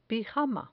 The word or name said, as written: pijama